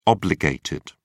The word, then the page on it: obligated: British English